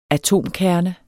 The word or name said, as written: atomkerne